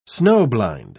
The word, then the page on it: snow-blind: British English